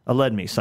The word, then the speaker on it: Aledmys: Aledmys, I guess, to make sure I pronounce his name correctly.